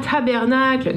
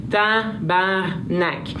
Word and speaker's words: tabarnak: Alors, Chris, euh, le sti qu'on peut substituer seulement par euh, sti, ou encore le tabernacle, s'il vous plaît, qu'on ne prononce pas à la française. ah, tabernacle! Non, non, non, non, non, non, non tabarnak.